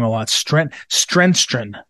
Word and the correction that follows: Stenstrom: I think I'm getting it right. Stenstrom. There it is. Stenstrom.